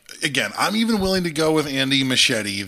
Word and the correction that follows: Muschietti: that, like, or was Machete? Is that his name? I apologize if I'm butchering his name. – Muschietti is what I'm going with. Muschietti, yeah.